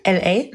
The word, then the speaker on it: L.A.: L.A., Los Angeles.